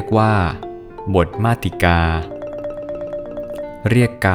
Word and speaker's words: มาติกา: สวัสดีครับพบกับรายการคำวัดวันนี้ขอเสนอคำว่ามาติกาคำว่ามาติกาสะกดด้วยมอม้าสละอาต่อเต่าสระอีกอไก่สละอาอ่านว่ามาติกา